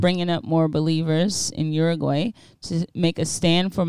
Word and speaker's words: Uruguay: I heard Uruguay, or that's how you say it? – Uruguay, yeah.